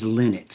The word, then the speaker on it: Lynott: I've asked many people how to pronounce it, and what I understand is the correct pronunciation is Lynott.